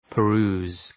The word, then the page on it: peruse: pə’ru:z